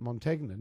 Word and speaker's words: Montagna: Lee Montagne, who plays for St Kilda, has a family that has always pronounced it Montagna. Now, that is manifestly wrong in a traditional Italian sense, but you're allowed to pronounce your name as you see fit.